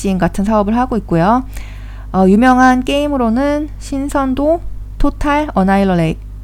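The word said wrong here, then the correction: Total Annihilation: 토탈 어나이얼레이션, 성경 전설, 라그나 로크와 같은 게임을 소유하고 있습니다.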